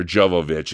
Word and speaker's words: Jovovich: who's played by Mila Jovanovic uh, or Jovovich, excuse me, I'm saying her name wrong, um, who literally drops out of the sky and into his life.